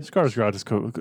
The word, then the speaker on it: Skarsgård: Skarsgård, it's close enough.